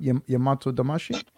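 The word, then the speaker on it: Yamato Damashi (大和魂): Because it's a, am I saying that right? Am I messing that up? Yamato Damashi? – No, you're saying it good. That's good. That's exactly how it's said.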